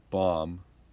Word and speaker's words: bomb: So it's pronounced like WOOM, and tomb is pronounced TOOM. But bomb is not. It doesn't follow that. – Tomb? – Tomb and womb, but bomb. – Maybe it's not a boom. – That's right, but it's how it's spelled. – No, but the other ones have a double M, right? – No. Womb is spelled W-O-M-B. Tomb is spelled T-O-M-B. And bomb is spelled B-O-M-B. – Okay. – So it doesn't follow the other ones.